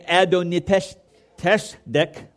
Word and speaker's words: Adoni-Tzedek: And therefore, King Adoni-Tzedek, I know it doesn't spell that way, but that's the way the Hebrew says it.